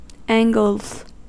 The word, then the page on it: angles: American English